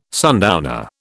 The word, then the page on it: sundowner: British English